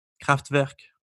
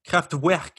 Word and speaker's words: Kraftwerk: He's one of the two founding members of a band, a very iconic band called Kraftwerk. How is that pronounced? – Beautiful. – Thank you so much. Because, you know, for a while I thought it was pronounced Kraftwerk, you know? – Or like a UE or something?